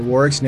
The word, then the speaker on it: Warwick: And on LinkedIn, you can look at us for Warwick's name, at Warwick Fairfax, with the silent W in the middle, W-A-R-W-I-C-K, at Warwick Fairfax on LinkedIn.